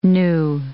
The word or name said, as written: knew